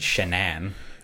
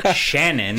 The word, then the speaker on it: Shanann: I don't like that her name is pronounced Shanann. – Shanann? – Shanann. It's fucking spelled as Shannon.